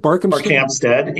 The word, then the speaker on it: Barkhamsted: We're talking, who is currently, and you're going to have to, Barkhamsted? Is that what you said? – Barkhamsted and Winchester. – Okay. All right. See, I mispronounced.